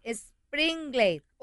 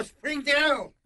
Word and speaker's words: Springdale: Springdale. – ¡Oh, Springdale! – ¡Springdale! – ¡Oh, my God! – Ahí vamos a estar. – ¡Springdale!